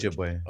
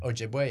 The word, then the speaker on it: Ojibwe: Ojibwe. – Ojibwe, yeah.